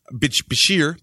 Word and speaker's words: Bichir: Damien Bichir. Bichir? Bichir. – Okay. – Bichir. Bichir. Bichir, B-I-C-H. – Oh my God, B-I-C-H-I-R.